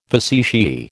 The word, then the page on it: facetiae: American English